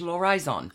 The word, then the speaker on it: l'Horizon: You don't pronounce the H, l'Horizon.